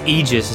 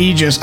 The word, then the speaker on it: Aegis: I believe it's Aegis is how you pronounce the word. Mm-hmm. – It is in fact Aegis.